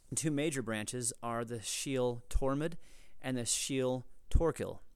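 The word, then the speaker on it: síol: And one of those words is shiel. Shiel is pronounced, or it's spelled S-I-O-L.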